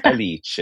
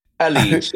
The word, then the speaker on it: Alice: So not to pull Italian pretentious rank on you, but her Elise Rohrocker is Alice Rohrocker. – And really? – Alice Rohrocker. – I keep calling her Alice, like Alice's restaurant. – Yeah, I mean, you know, that's how it's spelled, and you know.